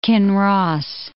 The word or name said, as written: Kinross